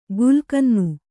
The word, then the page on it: ಗುಲ್ಕನ್ನು: gulkannu